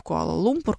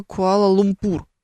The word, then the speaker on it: Куала-Лумпур: С ударениями в названиях вообще большая беда. Всегда перепроверяю, всегда перепроверяю. Знаете, помните, когда малазийский Боинг, который летел в Куала-Лумпур, упал, все почему-то думали, что она Куала-Лумпур. Или Куала-Лумпур даже, как-то так. Поначалу все так произносили.